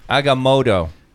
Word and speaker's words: Agamotto: By the holy host of Haga, by the eye of Agamotto, huh? – Agamotto. They actually pronounce it correctly.